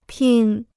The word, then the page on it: 拼: pīn